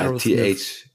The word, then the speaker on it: Aerosmith: Genau, genau ja, Aerosmith. TH, äh, TH ist echt schwierig.